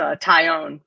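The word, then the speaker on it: Taillon: You're looking at Cole, Cortez, Montas, Talion, Herman, German. How do you pronounce it? – It's Herman, and it's Tyone, Jameson Tyone, like a tie that you own.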